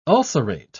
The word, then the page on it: ulcerate: British English